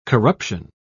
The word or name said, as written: corruption